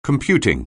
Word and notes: computing: anglais

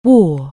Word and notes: wear: anglais

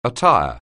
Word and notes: attire: anglais

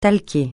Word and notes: talquer: francais

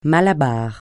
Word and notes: malabar: francais

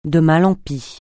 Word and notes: de mal en pis: francais

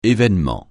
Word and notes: événement: français

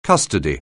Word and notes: custody: anglais

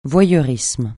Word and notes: voyeurisme: francais

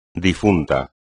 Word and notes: difunto: espagnol

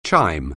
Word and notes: chime: anglais

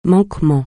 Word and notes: manquement: francais

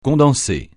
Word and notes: condensé: francais

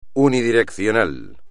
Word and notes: unidireccional: espagnol

